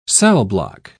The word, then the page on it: cellblock: British English